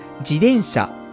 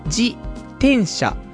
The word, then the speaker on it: 自転車: 私は関西人で自転車という時の発音自体が違うのですが、関東では自転車、一定の音というのが一般的なんでしょうか。ちなみに関西では自転車、かっこ手が高くなる自転車という感じの発音になります。